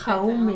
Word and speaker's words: Jaume: Donc, Confiteor de Jaume Cabré. Je sais pas exactement comment ça se prononce. Jaume hein. Cabré.